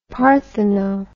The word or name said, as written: partheno